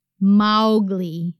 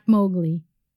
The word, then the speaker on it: Mowgli: As per Kipling, it's pronounced Mowgli, not Mowgli. That's your TIL for the day.